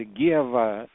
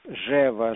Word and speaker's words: Гева: Псевдоним Гева, он по-разному в разных странах произносится. Гева, Жева, Жева.